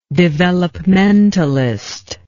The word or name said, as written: developmentalist